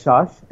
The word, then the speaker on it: Shash: And so, yeah, my name is Shash, S-H-A-S-H. You can reach out to me on Telegram. Saurian X, S-A-U-R-I-A-N-X.